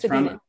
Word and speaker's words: Spadina: Still have to force ourselves to say Spadina. It's Spadina.